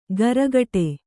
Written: ಕರ್ಕಡೆ

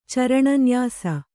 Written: ಚರಣನ್ಯಾಸ

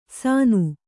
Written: ಸಾನು

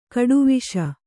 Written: ಗೆಡೆವಸು

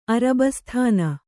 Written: ಅರಬ್ಬಿಸ್ತಾನ್

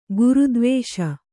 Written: ಗುರುದ್ವೇಷ

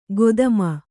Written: ಖದೀಮ

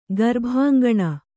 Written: ಗರ್ಭಾಂಗಣ

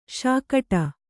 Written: ಶೇಕಡಾ

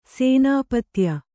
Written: ಸೇನಾಪತ್ಯ

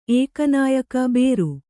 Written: ಏಕನಾಯಕ ಬೇರು

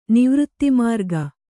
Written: ನಿವೃತ್ತಿಮಾರ್ಗ

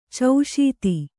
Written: ಚೌಶೀತಿ

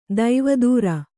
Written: ದೇವದಾರು

